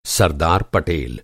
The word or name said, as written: सरदार